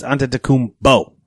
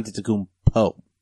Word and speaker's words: Antetokounmpo: Officially, it's Antetokounmpo, not Antetokounmpo.